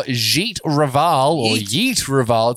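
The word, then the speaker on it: Jeet Raval: Yeet Raval. It's a soft J.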